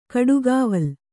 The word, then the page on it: ಗಿಡಗಾವಲು: giḍagāvalu